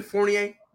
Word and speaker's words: Fournier: Y'all really let Evan Fournier? – Yeah. – Come on now. Dude, so whack. You didn't even pronounce the R in his last name. That second R. It's Fournier.